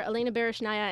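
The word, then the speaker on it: Berezhnaya: Did you see the Olympics in 2002 when the pairs team of Jamie Sillay and David Peltier and the, oh my gosh, I'm going to mispronounce the name, Anton Sikhorelidze, the Russian pair, Elena Baryshnaya and Anton Sikhorelidze. – That is correct.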